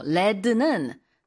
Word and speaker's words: lead: 레드는 납이에요. LEAD인데 리드라고 읽지 않고요. 납일 때는 레드 이렇게 발음하거든요.